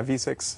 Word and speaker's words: VSIX: Yeah, V-S-I-X. Yeah, V6.